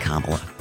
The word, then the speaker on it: Kamala: Except it's pronounced Kamala.